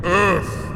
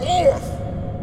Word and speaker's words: Earth: And yet you pronounce Earth, Orth, with an O!